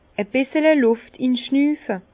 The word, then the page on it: e bissel Lùft inschnüfe: Bas-Rhin; Herrlisheim